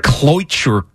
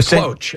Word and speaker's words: cloche: It's like cloiture or something, whatever. When they took that off and there was a... – It's a cloche. A C-L-O-C-H-E. – Okay. Cloche? – I'm assuming that's how you say it.